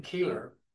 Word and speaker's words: Keeler: My name is Stephen Keeler. Last name is spelled K-E-E-L-E-R, a resident of Edmonds, Washington.